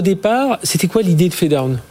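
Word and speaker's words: Phedone: Alors Fedown, ça s'écrit P-H-E-D-O-N-E e pour ceux qui nous écoutent en radio.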